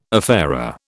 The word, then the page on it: affair: British English